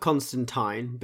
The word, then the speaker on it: Constantine: It's spelt Constantine, but it's Constantine.